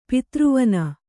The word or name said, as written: ಪಿತೃವನ